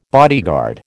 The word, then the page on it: bodyguard: American English